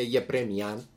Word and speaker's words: Γεπρέμιαν: Ο Γκάραπετ Σαρκής Γεπρέμιαν γεννήθηκε στη Λάρνακα στις 12 Ιουνίου 1944. Μια μικρή παρέθεση πριν να πάμε παρακάτω. Ξέρω ότι τα αρμενικά ονόματα τονίζονται στη Λίγουσαν, οπότε είναι Γεπρέμιαν κανονικά.